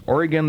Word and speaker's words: Oregon: There's a little town up by Toledo where they pronounce it Oregon. And if you pronounce that Oregon, you're in deep kimchi. So not knowing the correct pronunciation of the state, I guess I pronounce Oregon incorrectly. So remember, it's Oregon the state, Oregon, the city.